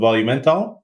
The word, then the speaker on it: Volumental: Today, we have a very special guest with us, Alper, the CEO of Volumental.